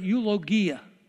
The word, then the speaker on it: eulogia: It just comes from this word, eulogia. In Greek, we don't have a soft G like a J, so we bring it over, eulogy.